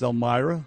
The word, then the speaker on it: Elmira: That would be pronounced Elmira.